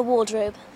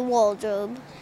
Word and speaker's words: wardrobe: She thinks there's a magical world in the wardrobe. – Start saying war. Wardrobe. Like almost as if you don't pronounce the R right. Like wall. – Wardrobe. – Like almost as if you're saying wall. Like wall.